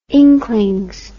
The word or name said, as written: inklings